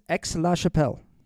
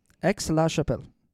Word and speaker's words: Aix-la-Chapelle: It's pronounced X. – Oh, it's Aix-la-Chapelle. – Yes. – Thanks for looking that up. – Yeah. – Aix-la-Chapelle. I just feel like that's French, and like the X would be silent, like Bordeaux.